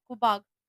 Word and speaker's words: Quaboag: Is it Cobog? Cobog? – What's it? – Q-U-A-B-O-A-G? – Yeah, Cobog.